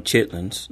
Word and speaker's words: chitterlings: Well, the main one is chitterlings, and we call them chitlins, not right. Chitterlings. – Right. – But I never heard it pronounced the other way. – Which way? – The first one. – Chitlins? – Yeah. – Or chitterlings? – That one. – Yeah, well, that's they're spelled chitterlings, but yeah, it's chitlins.